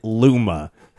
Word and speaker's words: Luma: Is is it Luma? It's eight bit versus ten bit versus twelve bit Luma. Am I saying that correctly? You are saying it correctly.